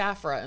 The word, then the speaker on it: Aphra: She was just Aphra in the first book. And in the second book, she was Dr. Aphra.